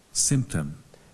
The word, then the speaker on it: symptom: Symptom. – One more time. – Symptom. – Your turn. Symptom. Symptom. และเรียนปลายทางอย่าลืมออกเสียงตามคุณครูและเพื่อนๆต้นทางด้วยนะครับ Everybody one more time symptom you stress on the first syllable symptom